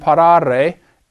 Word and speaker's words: parare: First conjugation has A-R-E in the infinitive. Parare. That's a long A.